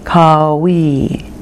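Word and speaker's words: Qawi: And the sacred word that we're going to use is Kawi. Kawi. Okay? Okay. And actually, the emphasis is on the we. It's a little elongated. Ka-we.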